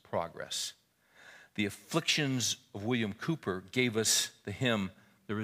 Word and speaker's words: Cowper: Or it looks like Cowper, but it's pronounced Cooper.